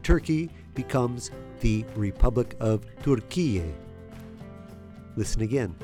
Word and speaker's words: Türkiye: With the two dots above the U, it's pronounced U, similar to the sound of U in the English word pure. Türkiye.